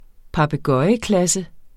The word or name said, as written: papegøjeklasse